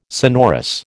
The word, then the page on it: sonorous: American English